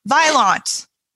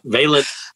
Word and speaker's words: Vaillant: Because like every sadly underinformed American person, I was like, Vaillant. And I'm like, that's not right. So Vaillant.